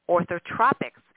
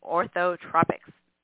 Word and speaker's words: Orthotropics: He is the lead clinician and lecturer at the School of Facial Orthotropics. I don't think I said that right. Orthotropics. There we go.